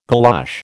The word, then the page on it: galosh: American English